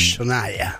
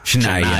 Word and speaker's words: Shania: El nombre es Shania. – Shania. Sí. – Pues se escribe Gina, ¿no? – Porque... Sí, se escribe Shania.